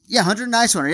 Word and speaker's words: Nyswander: And even Nyswander, or wait, was it Nyswander? Man, he had a kind of a wild name that was kind of tough to pronounce for a while. – Hunter Nyswander. – Hunter Nyswander, yeah.